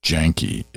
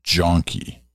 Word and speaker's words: Janke: Susan Janke's note. Oh, sorry. It's not Janke. It's Jonky. All right. It's spelled Jan, but it's John. So Jonky. Susan Jonky.